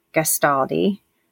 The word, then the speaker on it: Gastaldi: And we have our very special guest, Bree Gastaldi. I said that right. Correct, Bree? Did I say your last name correct? I thought so. – Yeah. – All right. Thought I nailed it.